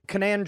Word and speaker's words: Kanan: Uh, Kanan Jarrus or whatever. Oh yeah, in uh uh was it Clone Wars or Rebels? Rebels. Can- Kanan Jarrus.